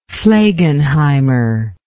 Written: flegenheimer

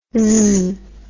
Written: zzz

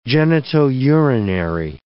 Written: genitourinary

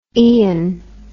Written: ean

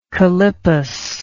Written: callippus

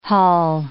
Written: haul

